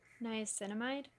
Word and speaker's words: niacinamide: And then I will let that dry after a few minutes, and then I'll use the Ordinary 10% niacinamide. How do we decide how to pronounce it? – Niacinamide. – Okay, well, I was way off.